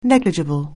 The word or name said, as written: negligible